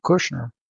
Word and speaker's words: Kushner: and then we have Jared Kushner. I believe I'm pronouncing it right. – Yep.